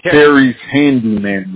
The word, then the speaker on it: Terry's Handyman: And then your email still, Terry Shandy, Repairs. – No, it's actually Terry's Handyman Repairs. – I don't know why I pronounced it like that.